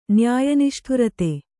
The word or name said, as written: ನ್ಯಾಯನಿಷ್ಠುರತೆ